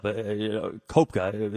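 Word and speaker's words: Koepka: Tiger Woods tied, not at its second, with Kepka, whatever, at 11 under. The name is just one of those things I messed up. Kopka is actually how it's pronounced.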